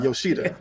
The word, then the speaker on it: Yoshida: I always butcher his name, but yeah, Yoshida.